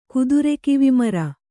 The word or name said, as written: ಕುದುರೆಕಿವಿಮರ